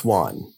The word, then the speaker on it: Juan: It's pronounced Juan. All right. We all know that. – And Byron, not the Byron, Spanish, though. – But Byron also pronounced it Juan. And we know because he rhymes Juan with new one.